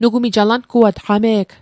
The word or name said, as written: alanguat